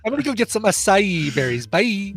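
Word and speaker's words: açaí: I think that's how I was told because I used to pronounce it acai berries. – Yeah, but it's acai berries. – Acai berries. – I believe somebody in the chat room is going to acai. Yeah, okay, so it's three syllables.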